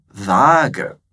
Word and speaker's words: Waage: Essa palavra é escrita com dois As, por isso que a gente pronuncia ela longa, né? Vaga.